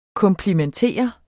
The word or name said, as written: komplimentere